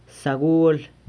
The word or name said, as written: sagual